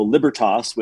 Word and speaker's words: Libertas: But there's two schools of thought. There's like the Germanic, you know, libertas, which most people say, because yep.